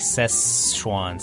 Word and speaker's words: Szechuan: 川是一样，但这个四是不是 SI 而是 SZE，然后 很多美国人会把它念成 Szechuan。 Yeah, so like kind of like a 发音有点像 SES like Szechuan Szechuan。